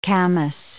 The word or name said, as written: camas